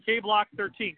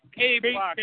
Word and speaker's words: K13lock: It's K-Block 13, correct? – No. It's K-Block, bro. Well, it's spelled this way, as you know, because you're subscribed. And if you're not, then he's going to kick you in the nuts. – All right. – Well, it's spelled K-1-3-L-O-C-K, the 1 and 3 make a B.